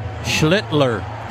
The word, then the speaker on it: Schlittler: His last name is spelled S-C-H-L-I-T-T-L-E-R. And as Ben said yesterday, that second L is very important. to emphasize.